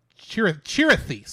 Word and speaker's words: Chirithy: And also, there we do find out that the Grey Cat's name actually is Chithery. I think I said it wrong earlier. It's Chirithy. Sorry, it is Chirithy.